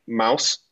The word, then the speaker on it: Maus: Art Spiegelman was actually speaking, not on campus, not physically on campus, but like to Central Michigan students, about his work, Mouse, M-A-U-S is how you pronounce it, which is like a comic book about the, like his father's story of living through a concentration camp and the Holocaust.